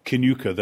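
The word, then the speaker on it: Kilconquhar: And Vain is, of course, as you know, spelt M-H-E-A-D-H-O-I-N. but then I live near a village which is called Kinuka, though it's spelt Kilconquer.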